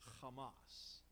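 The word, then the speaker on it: Hamas (חמס): Now, you might be surprised that the English word in our text, violence, is a Semitic word that's pronounced Hamas.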